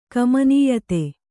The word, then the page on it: ಕಮನೀಯತೆ: kannada